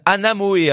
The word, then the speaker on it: Anamoé: Anamoé, e, hein, ça s'écrit A-N-A M-O-E.